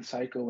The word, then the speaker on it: SYKO: Like it's pronounced Psycho. Yeah. Or yeah, man. Yeah. – How did you get that name? – Okay. So this story, well, one time, before I was writing Psycho and everything, that I was writing like three other names at the time because I didn't know what exactly to write.